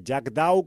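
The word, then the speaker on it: Jackdaw: Jack Dhow, como suena, J-A-C-K-D-A-W, a pesar de mi mala pronunciación del idioma shakespeariano.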